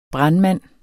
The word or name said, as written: brandmand